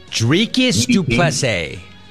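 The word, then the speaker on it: Dricus du Plessis: Dricus Duplessis.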